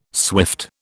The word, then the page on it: swift: British English